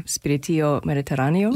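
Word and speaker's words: Spirito Mediterraneo: I'm Kathleen Cagioca, and I'm joined this morning by Spanish Baroque violinist Cristina Prats-Costa, whose album, I hope I'm pronouncing this correctly, Spiritio Mediterraneo? – You are. Very good.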